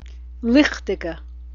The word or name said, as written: lichticheh